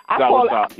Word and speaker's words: soursop: So can you tell us about soursop? I think I'm, sure. – If I'm saying it right. – Yeah, soursop. – Yeah, you're saying it. – You're saying it correctly.